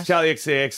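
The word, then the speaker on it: Charli XCX: Charlie XCX. Am I saying that right? – Is XCX? Charlie XCX. – Yeah. – Charlie XCX.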